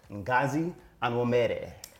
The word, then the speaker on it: Ngozi Onwumere: Welcome to the show, Ngozi Onwumere. – What's happening, y'all? What's good, girl? What's good? – I said it right. Yep, yep.